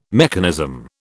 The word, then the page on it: mechanism: British English